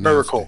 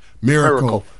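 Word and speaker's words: Mericle: Maris, I'm not sure how to pronounce Miracle. – It. Miracle construction firm, and operated by companies controlled by former local attorney, another lawyer, Robert Powell.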